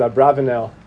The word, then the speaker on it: Abarbanel: There's an incredible question of the Abarbanel on the, some pronounce it Abravanel, on the Haggadah.